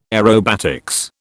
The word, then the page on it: aerobatics: British English